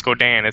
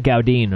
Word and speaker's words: Gaudin: It's, by the way, it's pronounced Godin. It's like French. – Oh, Godin. I said Gaudin, right? – Yeah.